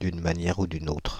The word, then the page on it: d'une manière ou d'une autre: France (Île-de-France); /d‿yn ma.njɛ.ʁ‿u dy.n‿otʁ/